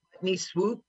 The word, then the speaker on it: Putney Swoop: One movie we love, Putney Swoop, right? What's that? That pronounce it right? – Putney Swoop.